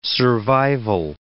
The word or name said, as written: survival